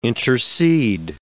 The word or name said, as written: intercede